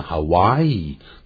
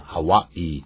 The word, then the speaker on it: Hawaii: 很多人念成 Hawaii，但到了夏威夷本岛的时候才知道，当地人说念夏威夷可变成 Hawaii，要念成 Hawaii。